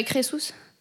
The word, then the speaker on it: Crésus: Je dirais Crésus.